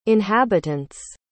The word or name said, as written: inhabitants